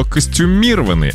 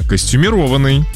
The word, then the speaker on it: костюмированный: Лариса нам написала, она говорит, что до сих пор многие неправильно произносят слово костюмированный, а правильно костюмированный.